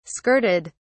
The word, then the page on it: skirted: British English